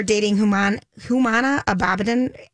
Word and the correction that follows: Abdin: Huma Abdin.